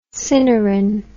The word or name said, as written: cinerin